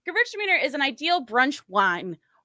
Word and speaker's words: Gewürztraminer: And a little pneumatic helper for you, if you're ever having trouble pronouncing this one, think girls are meaner. Gewürztraminer.